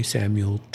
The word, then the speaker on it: Samuel: You know, that's me, by the way, Samuel Trapp.